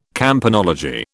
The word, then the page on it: campanology: British English